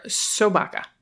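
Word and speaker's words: Sobaka (собака): Now, as a pet tax, I've included a pic of Baca, short for Sobaka. Am I saying that right? Yeah. Which is just dog in Russian.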